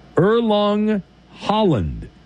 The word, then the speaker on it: Erling Haaland: I butchered his name last time, but I believe it's Erling Haaland.